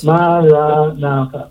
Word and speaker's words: Maranatha: Maranatha. Four syllables.